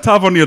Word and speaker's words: Tavernier: So I've had Tavernier down pat for ages.